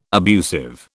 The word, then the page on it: abusive: British English